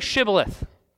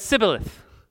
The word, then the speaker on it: Shibboleth: And when he said no, they said to him, then say Shibboleth. And he said Sibboleth, for he could not pronounce it right. He has an accent. He can't say the word right.